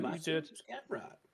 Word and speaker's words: Mateusz Gamrot: All right, we got Mateusz Gamrot versus Mateusz. – Did I say that wrong? – Mateusz Gamrot. – No, you said it right.